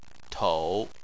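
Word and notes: tou: tone 3

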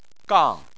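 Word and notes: gang4: tone 4

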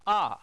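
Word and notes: a: tone 4

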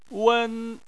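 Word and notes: wen: tone 1

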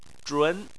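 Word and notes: zhun: tone 2

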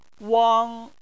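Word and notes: wang: tone 1

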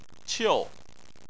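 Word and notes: qiu: tone 4

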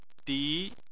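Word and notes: di: tone 2

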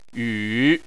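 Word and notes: yu: tone 3

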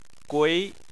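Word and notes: gui2: tone 2

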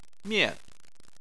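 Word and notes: mian: tone 4

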